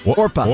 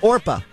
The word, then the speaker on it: Orpah: It's Orpah. – Orpah? – Orpah.